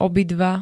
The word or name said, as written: obidva